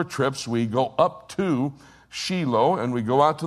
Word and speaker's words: Shiloh: They would go up to Shiloh. You pronounce that Shiloh. It's Shiloh in Hebrew.